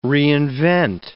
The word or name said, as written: reinvent